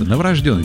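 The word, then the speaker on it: новорождённый: Но правильно говорить, действительно, новорожденный. Буква Ё вам будет вот для проверки.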